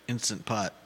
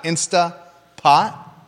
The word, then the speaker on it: Instant Pot: Instant Pot. – What is it? – Instant Pot. – Oh, it's not Instapot? – No.